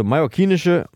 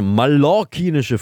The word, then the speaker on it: mallorquinische: Ja, also mallorquinische, jetzt habe ich wieder falsch gesagt, tut mir leid. Also mallorquinische Früchte heißt es. Mallorca heißt es ja.